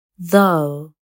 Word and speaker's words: though: ويمكن أيضا أن يصبح صامتا لما تأتي جي اتش بعد حرف متحرك مثل high daughter though